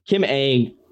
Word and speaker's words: Ng: Kim Ng. It's pronounced Ang. I had to look it up.